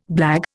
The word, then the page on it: blague: Indian